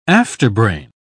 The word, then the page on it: afterbrain: British English